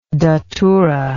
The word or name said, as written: daturic